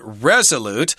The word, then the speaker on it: resolute: Now, here we've got the word resolute with the accent on the first syllable, resolute.